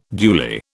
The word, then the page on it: duly: British English